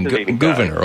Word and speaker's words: Gouverneur: Yeah, so it is probably pronounced Gouverneur, but we're not entirely sure. Governor Morris, we don't really know. – But that was his first name, Gouverneur.